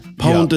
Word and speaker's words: pwned: Pwned is spelt P-W-N-E-D. – It's owned, but with a P. Have I been owned, but with a P, .com. – Is the P silent? – The P, technically, yeah.